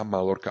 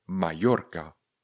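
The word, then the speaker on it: Mallorca: Spoustu lidí říká Malorka, ale pozor, Malorka se píše s dvěma L a s C a na, na konci. To znamená, správná výslovnost tohle slova je Majorka. Majorka. Majorka. Takže opravujte všechny kolem jdoucí, není to žádná Malorka.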